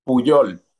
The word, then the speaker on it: Puyol: Pollo. How do you say it? – Puyol. – Pollo. Pollo. Okay. – Much better.